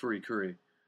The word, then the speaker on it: FLCL: Oh, yes, FLCL, correctly pronounced Furikuri, is absolutely batshit insane.